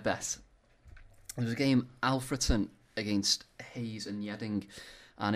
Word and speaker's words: Alfreton: Alfreton, I think it's pronounced.